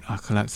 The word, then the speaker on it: akhlaq: There's another meaning in Arab is the akhlaq. Portray good akhlaq. So when we are... – Wait. Say it correctly. – Akhlaq. – Yeah. – Akhlaq. The truth, emphasize. – Correct.